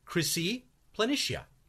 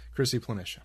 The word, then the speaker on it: Chryse Planitia: So, but that's how it's generally pronounced in the community. Chryse Planitia.